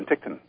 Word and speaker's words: Penticton: And I wasn't sure how to pronounce it. I'm glad you said Penticton. – Yeah. Penticton.